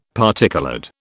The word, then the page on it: particoloured: British English